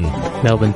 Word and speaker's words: Melbourne: Melbourne is Melbourne. – Melbourne. – With the bin part, almost a BN. Melbourne. And the I... okay. And without the I. So, Melbourne. Brisbane is Brisbane.